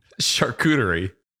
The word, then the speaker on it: charcuterie: Charcuterie.